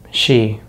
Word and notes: she: American English

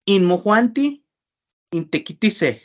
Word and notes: inmojuanti intekitise: Náhuatl

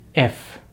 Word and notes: if: American English

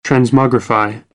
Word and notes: transmogrify: American English

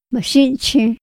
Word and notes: ma xinxi: Otomí

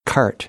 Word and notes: cart: American English; ɑːr

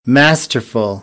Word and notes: masterful: American English; æ

